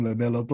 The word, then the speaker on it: LaMelo: Lamelo, L-A-M-E-L-O.